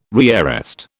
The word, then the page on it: rearrest: British English